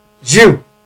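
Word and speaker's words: giù: Jew, unfortunately pronounced Jew in English, but meaning come down in Italian.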